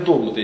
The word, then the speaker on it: догматы: По-моему, по-русски правильно говорить догматы, если не ошибаюсь. Вот я даже специально узнавал. Ну, можно догматами.